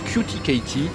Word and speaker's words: Cutie Katie: Cutie Cathy. – En anglais, ça se prononce Cutie Katie.